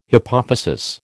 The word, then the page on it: hypophysis: American English